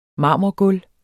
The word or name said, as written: marmorgulv